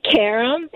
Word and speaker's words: Karim: Okay, so we say Karim, which you said the right thing. It's like Americanized, but when I was telling you earlier, it's really Karim.